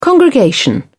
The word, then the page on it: congregation: British English